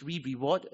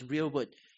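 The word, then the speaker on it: rereward: A very common pronunciation is re-reward. I've did that for actually decades of my life, but the right pronunciation actually is real word, right? You should see R-E-R-E as one word, like the word here, H-E-R-E, right?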